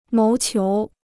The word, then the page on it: 谋求: móu qiú